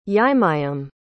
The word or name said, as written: yimeyam